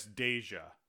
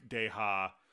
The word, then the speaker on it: Deja: and it's Andreas Deja. It's not, it's not the Hispanic Deja, but he did Gaston.